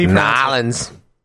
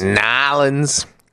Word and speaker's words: New Orleans: This happened in New Orleans? – Okay, I can see that. – No. Pronounce that wrong. – It's pronounced Nolens. – Right. Say one more time, please. – Nolens.